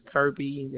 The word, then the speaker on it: Kirby: Yes, my first name is Kirby. It's spelled as K-I-R-B-Y. Last name, Macaulay, M-C-C-O-L-L-E-Y.